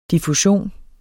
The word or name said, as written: DIFFUSIONEN